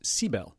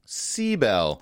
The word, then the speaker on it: Cybele: Okay, we had the emphasis wrong. It's Cybele. – Cybele. – The emphasis on the first syllable.